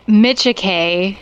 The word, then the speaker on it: Micciche: It's Micciche.